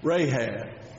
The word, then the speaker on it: Rahab: How do you say that? Rahab. Got it.